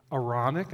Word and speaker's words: Aaronic: We're going to end with the Aaronic, am I saying that right? – That's right.